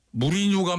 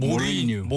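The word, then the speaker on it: Mourinho: 무리뉴 감독을 얘기하는 순간 놀랐습니다. – 네. – 사실 그 발음은 진짜 발음은 모리뉴. 모리뉴라고 그래요. 모리뉴. – 그렇구나. – 근데 네. 우린 다 무리뉴라고 그래가지고.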